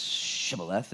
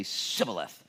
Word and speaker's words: Shibboleth: And if he said, no, they would say, well, then say Shibboleth, for they could not say and pronounce Shibboleth, and they would accidentally say Sibboleth, for he could not pronounce it right.